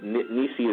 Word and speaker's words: Nissi: Jehovah Nissi, we said that Nissi is similar to Nissan or the banner.